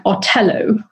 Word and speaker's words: Othello: And it could be pronounced more like Otello, which suggests that he's kind of an Ottoman. It sounds a bit like Ottoman. Mm. Or the other idea is that it sounds like Thorello, which is a name of a character in Ben Jonson's play, which is Every Man in His Humour, which is a comedy.